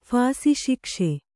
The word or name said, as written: ಫಾಸಿಶಿಕ್ಷೆ